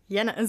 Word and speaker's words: Jenner: also in Gedanken habe ich den immer Edward Jenner ausgesprochen. Äh, obwohl ich wusste, dass es ein Brite ist. – Jenner, so wie Jenner, so wie, so wie in Österreich Januar.